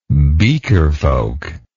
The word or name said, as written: beakerfolk